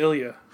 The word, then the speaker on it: Ilja: It's it's it's I L J A. – Dragonov. I think it's Ilya.